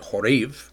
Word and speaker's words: Horeb: and I'm pronouncing it Horeb.